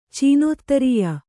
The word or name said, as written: ಚೀನೋತ್ತರೀಯ